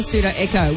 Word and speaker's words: Pseudo Echo: Olivia Dean on the way in the two right after Pseudo Echo with a silent P on Star.